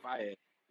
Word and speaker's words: Faiyaz: Is it not Fiaz? – I've always said Faiyaz.